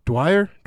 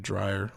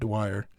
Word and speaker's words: Dwyer: So I went and listened to Dwyer. I always say Dryer, like an idiot. Dwyer.